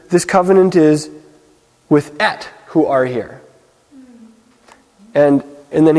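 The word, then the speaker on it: et (את): So, this, whenever I see the Hebrew term Aleph and Tav, which is pronounced et in the Torah,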